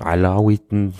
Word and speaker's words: Alawiten: unterscheidet häufig zwischen Aleviten, das ist das das türkische Wort und dem Alawiten in der arabischen Aussprache.